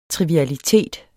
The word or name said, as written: TRIVIALITETEN